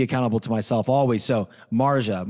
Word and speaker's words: Marjah: So first of all, ladies and gentlemen, that for those of you listening, I mispronounced. It's Marja.